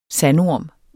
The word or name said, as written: SANDORMEN